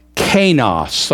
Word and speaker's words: kainos: It's the word kainos. I'll spell it for you. K-A-I-N-O-S.